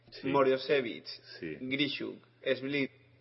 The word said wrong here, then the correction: Svidler: a ver si lo digo bien, Svidler eh, Tomaszewski, Yakovenko Andrejkin, Riazansev, Nepomniamchi y Malajo.